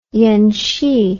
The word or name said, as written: yenshee